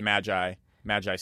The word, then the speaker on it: Maggi: In Mexico, it would be Hugo de Maggi, but it's not from Mexico. It's a Swiss product. – Right. – Magi seasoning. – Magi seasoning. We've written about Magi seasoning on Taste, and we've written about MSG and some other additives and how it's important in all cultures.